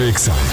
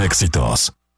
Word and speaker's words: EXA: Estudio EXA es un concepto de MBS Radio. – No se dicen éxitos, se dice EXA.